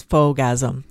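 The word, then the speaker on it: faux-gasm: Faux means fake, and it just rhymes with orgasm, you know, so it's fogasm. It just means fake. – I thought you said full as in F-U-L-L. – Oh, okay. No. Faux as in fake.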